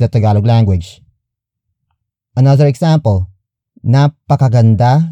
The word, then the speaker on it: napakaganda: Word for word, it's napakaganda ni Pia.